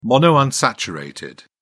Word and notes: monounsaturated: British English

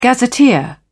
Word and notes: gazetteer: British English

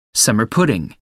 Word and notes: summer pudding: American English

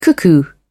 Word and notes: cuckoo: British English

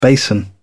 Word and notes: basin: British English

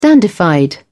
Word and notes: dandified: British English

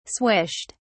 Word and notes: swished: British English

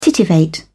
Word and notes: titivate: British English

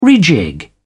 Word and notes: rejig: British English

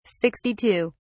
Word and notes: 62: British English

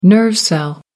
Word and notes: nerve cell: American English

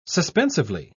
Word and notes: suspensively: British English

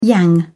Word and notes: yang: British English

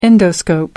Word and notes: endoscope: American English

